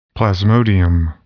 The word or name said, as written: Plasmodium